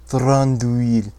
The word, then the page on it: Thranduil: Sindarin